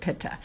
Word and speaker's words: pitta: The next dosha is pitta or pitta. I pronounce it pitta.